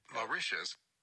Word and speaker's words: Mauritius: Mauritius. – Wait, Mauritius.